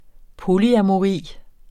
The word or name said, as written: polyamori